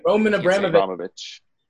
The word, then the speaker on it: Roman Abramovich: You know, Chelsea, like you said, how do you say the owner's Roman Abramovich.